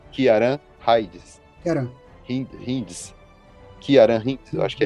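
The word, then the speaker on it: Ciaran Hinds: É C-I-A-R-A-N, só que como ele é irlandês, provavelmente, e eu acredito que é no gaélico irlandês, o C tem som de K, então é Kieran. – Kieran Hinds.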